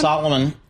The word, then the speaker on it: Salomon: and that's helped by demand for Salomon shoes. So I ended up, did I mispronounce that one? – Salomon, those are the ski boots I wear, Salomon.